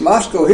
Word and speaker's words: Moscow: And I tell people, I said, no, it's not Moscow. Up in Idaho, it's Moscow. That's sort of like in Tennessee. They have Paris and Milan. Now, those are not the European pronunciations. You know, it's Paris and Milan. But in Tennessee, it's just plain old Paris and Milan. Well, it's Moscow here. At least I was told that on that previous visit here.